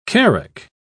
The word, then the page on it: carack: British English